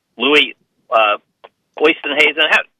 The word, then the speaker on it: Louis Oosthuizen: They got a 3-shot lead over Louis Oosthuizen.